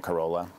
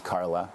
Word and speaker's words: Carola: I can sort it out here just because everybody calls it Carola, but it is actually pronounced Carla. It's a Swedish spelling of the word Carla.